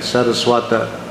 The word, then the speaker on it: Saraswata: But with the long A first and the A on the end, Saraswata.